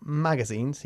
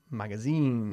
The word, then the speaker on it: magazines: Not magazines, sino magazines. – Magazines. – Magazines. – Magazines. – Enfatizamos la última sílaba, ¿ok?